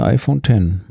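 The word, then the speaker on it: iPhone X: iPhone und dann war da so ein X und wir haben uns gefragt, wie wird es denn wohl ausgesprochen? Und nach dem ersten Video kam dann Tim wieder und sagte iPhone 10.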